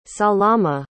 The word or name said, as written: salama